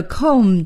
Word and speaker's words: comb: 这是谁的梳子呢梳子是我们在这课新学的单词 C-O-M-B comb 在这里啊还要提醒朋友们这个 comb 的 B 是不发音的我们也叫它 silent b 所以呢要念成 comb 那么怎么来回答这句话呢 Whose comb is this?